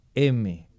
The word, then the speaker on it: heme: Y en cada una de esas unidades tenemos un grupo M. M, pero se escribe H-E-M-E.